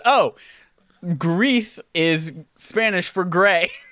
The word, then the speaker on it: Gris: Is that how this is pronounced? Mm. Gris? It is.